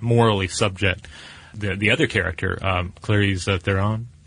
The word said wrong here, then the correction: Charlize Theron: Charlize Theron.